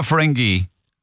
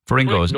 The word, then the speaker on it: Ferrigno: Is it Ferrigno or Ferengi? Ferrigno. – No, it's spelled with a G.